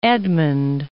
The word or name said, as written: Eadmund II